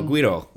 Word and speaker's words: güiro: No, güiro. Like it's a Spanish word. It's G-U-I-R-O.